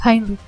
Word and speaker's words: Heinrich: O Heinrich, ¿cómo se dice? – Es Heinrich.